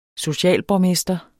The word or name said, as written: socialborgmester